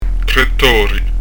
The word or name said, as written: tori